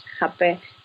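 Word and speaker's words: rapé: Oh, well, it's called, it's called Rabe, which is R-A-P-E, right? – Okay. – So it's not ideal, Rabe.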